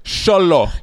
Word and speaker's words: Xolo: Is what's how you say his name? Holo? – I'm dead. Xolo.